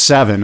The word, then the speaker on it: Severn: But just so you know, the River Severn, and it's pronounced Seven apparently, sits between England and Wales. And that's from a guy named Ha, H-U-W.